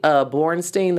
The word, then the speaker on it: Bornstein: But I also liked what went hand in hand with Candace talking about she was single is how Kate Bornstein, that's how you pronounce her last name, I really like how she opened up the conversation about chasers.